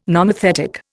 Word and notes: nomothetic: Indian

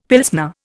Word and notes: Pilsner: Indian

